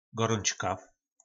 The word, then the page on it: goroncka: po nasimu